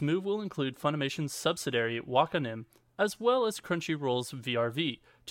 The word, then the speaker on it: Wakanim: Fans will enjoy even more anime as Funimation Wakanim? I could not understand how you would pronounce that, but I believe it's Wakanim, moved to Crunchyroll starting today.